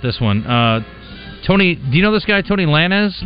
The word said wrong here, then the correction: Tory Lanez: Tory Lanez, thank you very much.